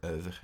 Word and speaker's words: oeuvre: Her oeuvre is the proper French pronunciation, but I digress.